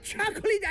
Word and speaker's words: chocolate: 네, 이 chocolate 이 클을 살짝 넣고 바로 레스로 넘어가야 돼요.